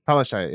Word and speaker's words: Palaszczuk: The Palazuki? How do you say it? – Palaszczuk, yeah, I forget.